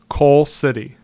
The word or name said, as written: Coal City